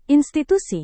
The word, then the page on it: institusi: indonesian